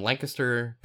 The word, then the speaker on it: Lancaster: and then I've also I had planned to talk about a school in Lancaster, Pennsylvania, actually. And yes, that's how it's pronounced, Lancaster.